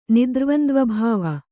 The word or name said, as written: ನಿದ್ರ್ವಂದ್ವಭಾವ